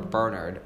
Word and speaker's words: Bernard: It's important to know that Bernard Nightingale, or Bernard, as the English people pronounce it. – Yes, the English pronounce Bernard.